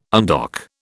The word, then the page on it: undock: British English